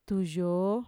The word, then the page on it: tuyoo: Mixtec